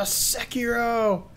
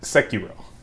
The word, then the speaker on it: Sekiro: And this game is called, I hope I'm not butchering this, Sekiro Shadows Die Twice. – Oh, Sekiro! – Sekiro.